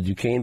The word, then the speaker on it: Duquesne: You see what LeBron did for those for the Duquesne basketball team? Am I saying it right? – Yes, Duquesne. Yeah, okay.